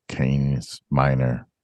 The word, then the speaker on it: Canis Minor: The constellation named Canis Minor is pronounced Canis Minor.